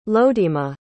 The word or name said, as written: lodema